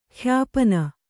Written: ಖ್ಯಾಪನ

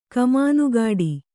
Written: ಕಮಾನುಗಾಡಿ

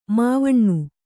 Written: ಮಾವಣ್ಣು

